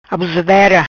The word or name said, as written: Abusadera